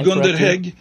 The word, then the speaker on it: Gunder Hägg: Yeah, Gunder Hägg yeah, that's that's the right pronunciation right there.